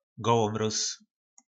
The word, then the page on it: golomroz: po nasimu